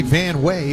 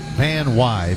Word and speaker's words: Van Wey: It looks like Van Wey, but it's pronounced Van Wy, V-A-N-W-E-Y.